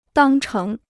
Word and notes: 当成: dàng chéng

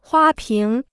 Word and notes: 花瓶: huā píng

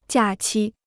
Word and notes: 假期: jià qī

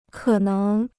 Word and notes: 可能: kě néng

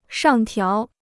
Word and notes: 上调: shàng tiáo

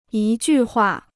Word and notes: 一句话: yī jù huà

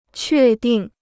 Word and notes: 确定: què dìng

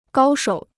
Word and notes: 高手: gāo shǒu

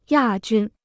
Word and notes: 亚军: yà jūn

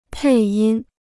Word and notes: 配音: pèi yīn

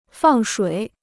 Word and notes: 放水: fàng shuǐ

